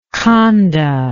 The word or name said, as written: khanda